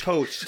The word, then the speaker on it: Thoth: I think Thoth.